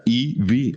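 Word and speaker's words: Evey: La, actriz que Natalie Portman encarna a Ivy, que se pronuncia Ivy, o sea, E y B chica. La E es la letra alfabeto y la B, pues es el nombre de B, o sea, como si fuera un bebé.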